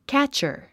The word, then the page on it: catcher: kǽtʃə r; キャッチャァ